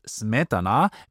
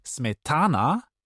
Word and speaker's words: Smetana: Der Name Smetana wird auf der ersten Silbe betont, Smetana. Wird er auf der zweiten betont, Smetana, dann bedeutet er im Russischen saurer Rahmen.